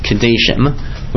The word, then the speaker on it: Kadeshim: The word actually was traditionally pronounced Kadeshim, which means what do you think it means?